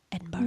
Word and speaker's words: Edinburgh: I have to say it like that. Edinburgh. Yeah. – Good job.